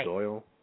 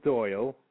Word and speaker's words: Doyle: Remember, you can, you know, drop me an email at Bill Doyle, D-O-Y. – Yeah, but it's okay. – I haven't finished it yet. – Oh, I'm sorry. – Bill Doyle, D-O-Y-L.